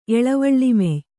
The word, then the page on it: ಎಳವಳ್ಳಿಮೆಯ್: Kannada